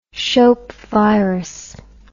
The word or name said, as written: shope virus